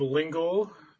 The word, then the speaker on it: Lingle: My name's Jacob Lingle. It rhymes with Chris Kringle for you older people out there.